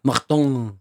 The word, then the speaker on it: Martin: Marton. Ja, bare sånn fransk uttale.